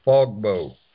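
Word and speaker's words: Fogbow: It's Fogbo. That site you're looking for is Fogbo. – That's right, the Fogbo.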